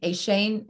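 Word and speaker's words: Achane: I mean, I'm sorry, a chain, a chain, a chain.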